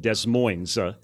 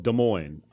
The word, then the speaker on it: Des Moines: The S is silent in the French. We don't call it Des Moines. We call it Des Moines, Iowa.